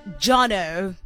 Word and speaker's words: Joneaux: It is Jono. That's how you pronounce it, but it's spelled J-O-N-E-A-U-X.